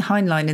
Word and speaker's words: Heinlein: Now, I'm pronouncing Heinlein in the German way.